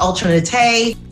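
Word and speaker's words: Ultra Naté: Almost in nutty. – You've got to unlearn how to say it. Mm. Yeah. Ultra Nate. Oh, don't say that. I'll say Ultra Nutty now.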